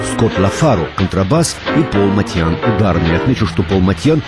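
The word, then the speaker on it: Motian: В первые годы 49 минут джаза я произносил его фамилию как Моушен. Потом великий знаток джаза, живущий нынче в Бостоне, Сабиржан Курмаев, написал мне, что Пол армянских кровей и что его фамилия и звучит по-армянски Матьян.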